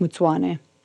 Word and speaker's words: Mutsvane: But Mutsvane is pronounced Mutsuane.